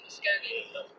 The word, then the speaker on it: Muscogee: I know how to say that correctly, Muscogee.